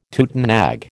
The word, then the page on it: tutenag: American English